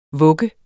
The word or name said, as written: vugger